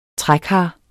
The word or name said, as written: trækar